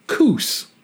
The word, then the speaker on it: Coos: I didn't know that, but I spent a lot of time in the Pacific Northwest where you have Coos Bay in Oregon that is pronounced Coos.